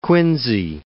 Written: Quincy

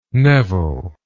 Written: nevil

